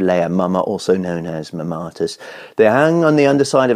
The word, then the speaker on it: mammatus: Tell us about the mammatus. – Well, you pronounced it wrong.